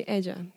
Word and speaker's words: Edja: So, but people call me Edja. That's my nickname. And how I explain it to everyone is like edge of something, edja.